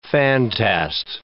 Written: fantast

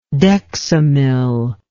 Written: dexamyl

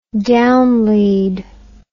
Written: downlead

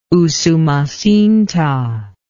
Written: Usumacinta

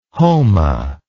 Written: homa

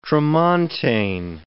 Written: tramontane

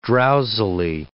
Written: drowsily

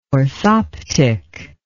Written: orthoptic

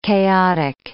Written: chaotic

